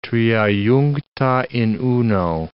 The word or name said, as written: tria juncta in uno